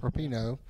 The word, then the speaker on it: Rapinoe: So, Megan Rapinoe, is that how you say her name? – Rapinoe.